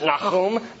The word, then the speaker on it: Nahum: And we're going to pronounce it Nahum the whole time, just for fun.